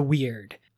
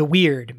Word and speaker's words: wyrd: And that is known as the weird. Again, spelling, not as you think it, W-Y-R-D. There's probably a better pronunciation as well.